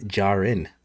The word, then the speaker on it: Djarin: First, he announces that he knows Mando's real name, which is Din Djarin.